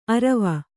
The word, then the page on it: ಅರವ: Kannada; arava